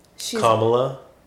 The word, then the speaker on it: Kamala: She's Kamala.